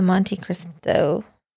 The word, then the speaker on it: Monte Cristo: I want the Monte Cristo. That's how you say it, right? – Yeah.